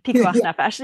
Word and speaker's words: pikuach nefesh: Is that how you say it? – Pikuach. – Pikuach. – Yeah, with a K. Pikuach nefesh.